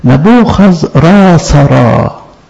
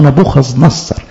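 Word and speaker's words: نبوخذراصر: نبوخذ راسرا هو نفسه نبوخذ نصر، بس نبوخذ راسرا بالنطق الكلداني للاسم اللي احنا بنقوله في معظم الكتاب. نبوخذ نصر ده الاسم الارامي، الارامي ده العامي بتاع العبري، فهم نفس الشخص بس النطق الكلداني نبوخذراسر.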